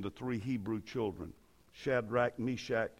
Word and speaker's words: Meshach: And I heard one preacher who was not as educated as some of the others mispronounce their names. Meshach, Yushach, and to bed we go. That's what he said.